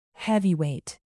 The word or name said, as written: heavyweight